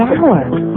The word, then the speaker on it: hour: H is not a vowel sound. It's a consonant sound. – But it's not the first sound. The H is silent. The hour.